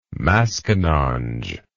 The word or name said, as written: maskinonge